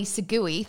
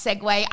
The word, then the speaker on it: Segway: I often mispronounce something deliberately, i.e. Segui, thanks to all the people that DM'd me this week and wondered if I was meaning Segway. Yes, I know that that's how you pronounce Segway. I just pronounce it in the literal way that it is spelt."